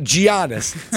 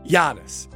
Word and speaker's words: Giannis: Now make sure it's not Giannis. Silent G. Giannis.